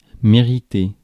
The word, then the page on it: mériter: [me.ʁi.te]; France